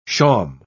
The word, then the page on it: shawm: British English